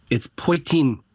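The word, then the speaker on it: poitín: I think we reached out to Brendan and said that we're interested, but I do think you're pronouncing it wrong. It's poitin. It's poitin. – Okay. – It's how we say it here.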